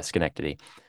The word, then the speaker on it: Schenectady: Yeah, Schenectady.